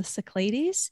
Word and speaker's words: Cyclades: pronounced the Cyclades.